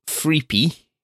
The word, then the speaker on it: freepy: Free for now inevitably moves into what I call freepy, free and then PI for personal information, which rhymes with creepy.